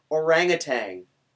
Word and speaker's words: orangutan: Orangutan. – There's no G.